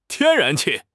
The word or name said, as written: 天然气